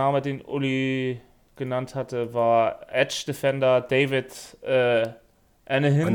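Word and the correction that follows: Anenih: Anini. – Anini. – Anini. So, so spricht man das aus.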